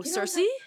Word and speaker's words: Circe: Like, I'm going to mispronounce it. Like, Circa. – Oh, Circe? – Circe. Oh, my God. I butchered that.